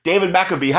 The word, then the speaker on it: David McAbee: Today I have the pleasure of interviewing, again, the second time on this show, actor, director, producer, David McAbee.